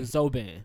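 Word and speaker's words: Zobayan: I think I'm saying that right. – Definitely saying the last name right. – Okay, Mr. Zoban, who was 50 years old, also passed away on that plane.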